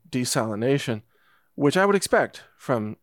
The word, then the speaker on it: desalination: And by the way, just as a quick note, desalination is the correct pronunciation of that term. I hear a lot of people say desalinization, which is they're adding an extra syllable. So just want to let you know that the correct way is desalination.